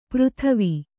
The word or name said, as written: ಪರಿದೇವ